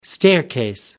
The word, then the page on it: staircase: American English